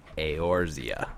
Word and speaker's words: Eorzea: Eorzea.